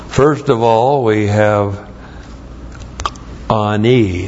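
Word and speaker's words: Ani (עני): There's the ayin. You have to actually close the throat and then start the word, and A-N, long I. Ani.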